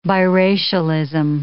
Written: biracialism

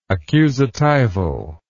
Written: accusatival

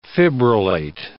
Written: fibrillate

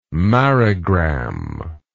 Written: marigram

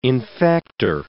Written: infector

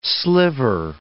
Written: sliver

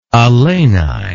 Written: Alani

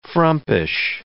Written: frumpish

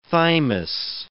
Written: thymus

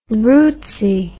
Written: rootsy

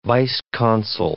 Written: vice-consul

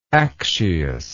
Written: accius

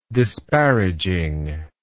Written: disparaging

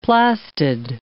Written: plastid

